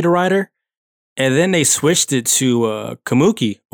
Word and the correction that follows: Takumi: or Takumi. I'm butchering his name. Takumi.